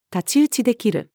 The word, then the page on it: 太刀打ちできる: female